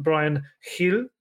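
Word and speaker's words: Bryan Gil: Obviously, Galini will talk a little bit about in a bit, and Brian Hill, I'm told to pronounce it as if I'm trying to spit on the microphone.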